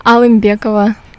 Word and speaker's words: Alymbekova: Alimbekova.